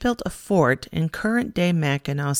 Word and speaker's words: Mackinac: To get to Mackinac Island, and that's pronounced Mackinac, you take a boat from either St. Ignace from the north or Mackinac City in the south.